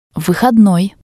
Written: vihodnoi

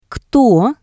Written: kto

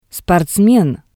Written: sportsmen

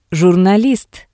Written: jurnalist

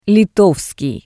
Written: litovskii

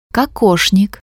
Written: kokoshnik

